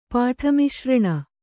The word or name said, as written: ಪಾಠಮಿಶ್ರಣ